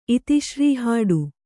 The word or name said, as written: ಇತಿಶ್ರೀಹಾಡು